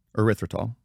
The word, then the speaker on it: erythritol: I love they word it so well, replacement called erythritol.